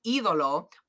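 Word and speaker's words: Ídolo: So Nigel said it right because he emphasized the right do. – Yeah. – The E, because so it's Idolo, but poor Ian was emphasizing the wrong thing, and he said the Idolo, and I said, damn it.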